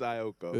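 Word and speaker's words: Aiko: How'd you say her name? – Jhené Aikea. – What you about to say her? I didn't know it. – I thought it was Ioka. – It's Ioka.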